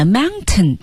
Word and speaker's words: mountain: Blue Ridge Mountain, Shenandoah River。 – 对，其实本来 mountain 这个单词呢，在口语里是要喉音停顿的，要读成 mountain。